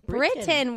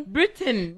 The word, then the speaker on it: Britton: Britain. – Where I'm going. – Britain. Yes. – Where I'm going to go. – But it's not spelled like that. – Okay. – B-R-I-T-T-O-N.